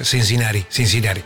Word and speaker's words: Cincinnati: Creo que es a ver cómo lo digo, Cincinnati. – Sí, no es el Cincinnati, esa T no es como la pronunciamos nosotros en castellano. – Mi profesor de inglés, Cincinnati.